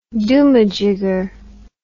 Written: doomajigger

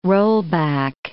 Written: rollback

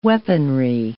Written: weaponry

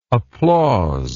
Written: applausive

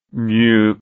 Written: Meung

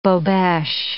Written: bobeche

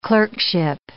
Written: clerkship

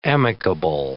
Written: amicable

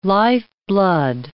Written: lifeblood

